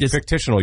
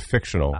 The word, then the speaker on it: fictional: But fictitional, you added syllable instead of fictional.